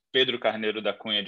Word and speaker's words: Pedro Carneiro da Cunha: But there are other ways of saying my name down in Latin America. You can like roll the R a little bit more. But the real way, the original way, is Pedro Carneiro da Cunha de Andrade.